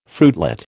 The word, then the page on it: fruitlet: American English